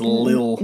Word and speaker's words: Lil Nas X: It's not little. It's Lil. – No, I didn't say little. Yeah. I said Lil.